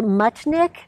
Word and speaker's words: Muchnick: I'm going to ask you again, Larissa, your last name is pronounced Muchnick.